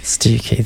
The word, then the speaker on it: Stiffkey: If it's not Stiffkey, what is it? – You're never going to get it. – I mean, Kelly and I both know Cornish pronunciations, but this no, I've got no idea. – Stickley, says Johnny. Oh. No, it's Stiffkey. Stukey. Not Stukey, Stukey. Stukey, there you go.